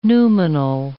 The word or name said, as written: noumenal